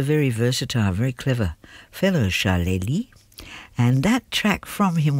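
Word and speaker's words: Charlélie: That was called Drifted, and it was from Charlely, unusual name, C-H-A-R-L-E, accent on the E, L-I-E, Charlely.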